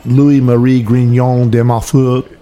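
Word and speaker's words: Louis-Marie Grignion de Montfort: Louis-Marie Grignon de Montfort.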